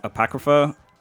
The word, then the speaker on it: Apocrypha: Apocrypha.